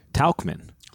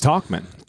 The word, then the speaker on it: Tauchman: See, did you know that growing up, Mike's last name was actually pronounced Tauchman? – No. – Yeah, I learned this the other day. But now Yeah, he's you know, he's Talkman.